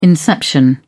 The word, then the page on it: inception: British English